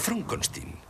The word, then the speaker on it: Frankenstein: Frankenstein. – Em vol prendre el pèl? – No, es pronuncia Frankenstein.